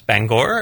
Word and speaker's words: Bangor: I've been learning how to pronounce Bangor and hitting that G, but all of that was very present in Bangor.